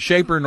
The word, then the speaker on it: Schaper: Well, it depends on how German you are, but Schaper normally works well.